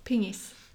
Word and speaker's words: pingis: Pingis. – Penis. Penis. You play. Penis when you play. – Penis. – In Sweden. – Pingis. Okay. You've never heard it? Pingis.